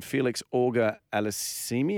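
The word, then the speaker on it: Félix Auger-Aliassime: Now, the bloke Alex Popperin defeated the Canadian Felix Auger Aliassime.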